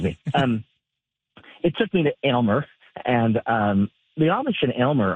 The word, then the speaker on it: Aylmer: My pronunciation of it, I think I finally have it right. Aylmer. – Aylmer. – Right? – Yeah, that's right.